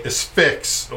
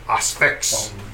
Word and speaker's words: Asphyx: So we're going to play Asphyx, or if you are Martin Van Drunen, you pronounce it Asphyx.